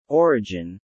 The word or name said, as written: Origin